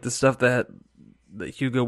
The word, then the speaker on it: Hugo: Hugo. Yeah, I like how you still say it wrong even after I just said it.